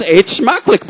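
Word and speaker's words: eet smakelijk: It's actually pronounced eet schmechlich, but that's very good.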